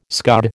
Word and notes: scud: American English

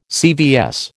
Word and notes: CVS: American English

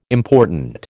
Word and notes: important: American English

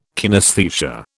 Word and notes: kinesthesia: British English

